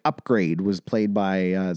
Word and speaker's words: Upgrayedd: So it's Upgrade, but it's not... It's spelled like UPGRAYEDD. – Double D.